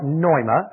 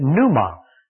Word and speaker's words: pneuma: The Greek word for Spirit is the word pneuma or noema, as most scholars pronounce it. I'm not a scholar, so I take the English pronunciation, pneuma, P-N-E-U-M-A.